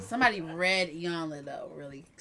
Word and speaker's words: Iyanla: How you say her name? – Iyanla. – Oh, there we go.